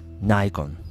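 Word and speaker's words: Nikon: ナイコン。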